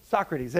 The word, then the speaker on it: Socrates: How do you say his name? – Socrates. – There you go.